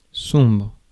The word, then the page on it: sombre: France; [sɔ̃bʁ]